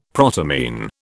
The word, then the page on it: protamine: British English